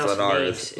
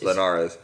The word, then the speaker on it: Linares: I think it's Linares. Linares.